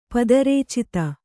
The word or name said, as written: ಪದರೇಚಿತ